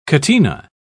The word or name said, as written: catena